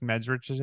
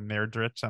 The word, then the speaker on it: Medrich: I had a funny experience the other day, which is that I made Alice Medrich's. Mm-hmm. I pronounced her name wrong and said Merdrich on my thingy, but it's Medrich.